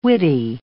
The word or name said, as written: witty